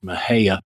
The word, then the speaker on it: Mexia: there's a town that it's spelled M-E-X-I-A. And of course, phonetically, that would be Mexia. Mm-hmm. And, you know, if you're an outsider, you look at that and you say, oh, this is the town of Mexia. Well, it's actually pronounced Mahia.